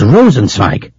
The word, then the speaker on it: Rosenzweig: It's Rosenzweig. R-O-S-E-N-Z-W-E-I-G. – R-O-S-E-N-Z-W-E-I-G.